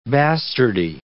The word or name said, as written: bastardy